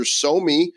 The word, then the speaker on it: SoMee: First, we want to tell you about our sponsor, SoMe, SoMe.social. It's spelled S-O-M-E-E. It's so you, it's so me, and it's a blockchain-based social platform that's built for privacy and user control and making money with your posts.